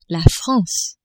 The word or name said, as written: France